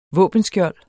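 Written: våbenskjolde

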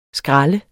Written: skrald

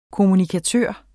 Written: kommunikator

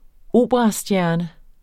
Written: operastjerne

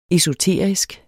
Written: esoterisk

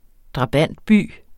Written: drabantby

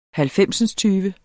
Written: halvfemsindstyve

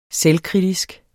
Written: selvkritisk

